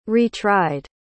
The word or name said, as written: retried